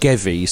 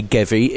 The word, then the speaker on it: GEWI: It's spelt G E W I, but it's pronounced Gevi. So if you walk around and think, who's that GUI that I've seen? It's actually Gevi.